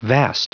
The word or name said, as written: vast